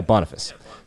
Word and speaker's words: Boniface: I have no idea how the British pronounce their words. Boniface. – Oh, that sounds right. It looks right. – Yeah, Boniface.